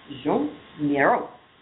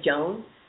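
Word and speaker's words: Joan Miró: You have to say it like that because it's French and there's a guy's picture next to it. So if you just say Joan, you're really doing a misservice.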